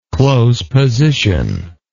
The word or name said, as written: close position